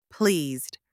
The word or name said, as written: pleased